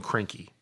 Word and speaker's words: Kroenke: And then number three is the biggest holder of Arsenal is Rams owner Stan Kroenke. That's how you pronounce his last name. So there you go. – Cranky bastard. – That's all out of the way. We clarified that.